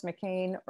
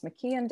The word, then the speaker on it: McKean: And if you look under Delaware, Thomas McCain or Thomas McKeon, depending on how you pronounce it and where you're from, he was one of the signers with Delaware, and he had not yet signed it.